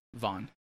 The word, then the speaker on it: Vaan: V-A-A. – Oh, V-A-A-N. – Yeah, it's a Hindu word. – V-A-A-N? – V-A-A-N. Vaughn.